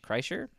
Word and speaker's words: Kreischer: Kreischer? Is that how you say his name? – Yeah.